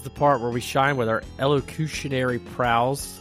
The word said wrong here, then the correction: prowess: Did I say that right? – No. – Prowess? – Elocutionary prowess.